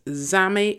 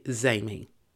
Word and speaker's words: Zami: I don't know how you would pronounce this. It's Zami or Zami. tomato, tomato, depends on how you want to pronounce it. I will say Zaymi.